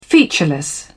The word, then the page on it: featureless: British English